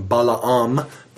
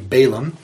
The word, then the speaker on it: Balaam: You would say his name Balaam, but we say Balaam.